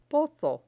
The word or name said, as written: pozo